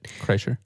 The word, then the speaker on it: Kreischer: So, Honeydew, he had Burt Kreischer. Mm-hmm. Is that how you say it? – Yeah, Kreischer.